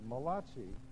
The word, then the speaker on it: Malachi: Malachi.